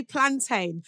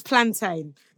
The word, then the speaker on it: plantain: I was just going to say, you say plantain, which is the correct way to say it. Let me categorically state that now. Plantain crew. Oh, yeah. – It's plantain.